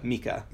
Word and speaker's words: Mika: Wait, how do you say your name? I assumed it was Micah. – Micah. – Micah, sorry.